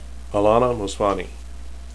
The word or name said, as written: alanna